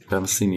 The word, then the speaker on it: Pat Metheny: Das ist This Is Not America von David Bowie und, wenn ich es richtig ausspreche, Pat Metheny. – Pat Metheny, ja.